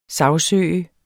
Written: sagsøger

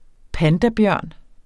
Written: pandabjørn

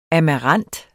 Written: amarant

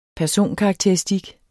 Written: personkarakteristik

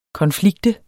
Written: konflikt

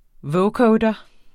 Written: vocoder

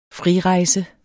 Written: frirejse

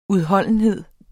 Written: udholdenhed

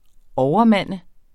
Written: overmand

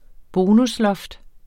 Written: bonusloft

